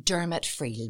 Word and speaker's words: Dermot Friel: Today, I'm joined by Dermot Friel, Managing Director of Friels Bar and Restaurant, also one of the leads on the Lochinchilin Tourism Cluster.